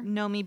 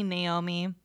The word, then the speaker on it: Nomi: Or a lot of people call me Naomi.